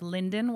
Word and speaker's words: Lynden: I represent the small but mighty town of Linden, Washington. – Okay. – That's spelled L-Y-N-D-E-N.